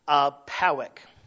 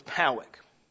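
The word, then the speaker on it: Pádraig: And it was written by a man named Padraig, or as we would call him, as you would pronounce it in the Gaelic, Pawick. His name was Pawick.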